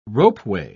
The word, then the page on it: ropeway: British English